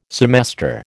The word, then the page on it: semester: American English